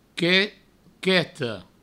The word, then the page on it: quete, queta: provençal; quete, queto